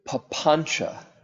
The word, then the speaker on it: papancha: There's one phenomenon that the Buddha spoke of that we get caught in, a particular phenomenon and problem that he called papancha. P-A-P-A-N-C with a little accent on it, A. So the C is pronounced like C-H. Papancha.